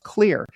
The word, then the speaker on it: Xlear: That's spelled X-L-E-A-R, but it's pronounced clear.